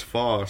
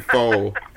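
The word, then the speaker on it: phở: No, no, like uh, Vietnamese guys ever stopped and said, uh, actually, it's pronounced fa, so don't come in my restaurant now. – Because yeah. you said faux. I know it says faux on the sign, but it's fa.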